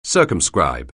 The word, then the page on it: circumscribe: anglais